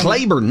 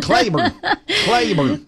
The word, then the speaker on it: Cleburne: He's the GM of Southwest Chrysler, Dodge, Jeep, and Ram in Cleburne. Wow, that was hard to say. Let me go again. – Cleburne. No, they say it that way. That's how they say it. Cleburne.